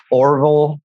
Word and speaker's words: Orval: So it's Orville, but it's spelled weird. It's O-R-V-A-L, like Orval. Wow. – Oh, like Marvel almost.